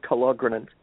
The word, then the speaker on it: Kalogrenant: I put the accent on the second syllable, Kalogrenant.